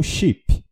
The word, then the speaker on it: chip: Bom, a segunda palavra a gente pronuncia em português como chip, que é o chip de celular. Em inglês, a gente pronuncia chip. Chip. Com som do I mais curto.